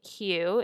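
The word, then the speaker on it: Hu: You may have seen Hugh, it's spelled H-U, products at Whole Foods or other specialty stores.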